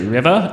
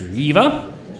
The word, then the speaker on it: Reva: I pronounced it River, and it's pronounced Reva.